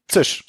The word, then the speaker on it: Czysch: Weil der Nachname, die hatten, also C-Z-Y-S-C-H. Wie wird's ausgesprochen? Zisch. Exakt. Also so wie Zisch. So wie man eine Dose Cola aufmacht. Zisch.